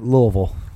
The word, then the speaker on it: Louisville: I'm saying that wrong, right? We just watched a show earlier and they discussed how you're supposed to say this word. – I knew I was saying it wrong the second I said it. I said Louisville. – Louisville. – What is it? Louisville? – It's like Louisville. – Louisville. – Like you're drinking syrup.